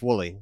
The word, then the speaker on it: Wooley: Wooley.